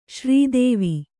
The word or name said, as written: ಶ್ರೀದೇವಿ